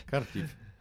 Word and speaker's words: Kharkiv: après, pendant que l'autre partie, à mon avis, là où ils ont eu la problématique, c'est euh, Kharkov. Bien prononcé. Kharkiv. Oui, moi je me prononce en russe, ah.